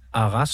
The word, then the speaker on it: Arras: Hvad er det helt præcis, der er sket fredag morgen i Arras, som jeg håber, du udtaler? – Det er fuldstændig korrekt udtalt.